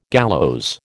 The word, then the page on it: gallows: American English